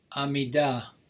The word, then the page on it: amidah: Hebrew